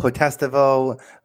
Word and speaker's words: Potestivo: Is that did I say that right, Vinnie? That's it. They, yeah, Ooh, Vinnie Potestivo, I... Potestivo. There's two sides of my family. They say it both ways.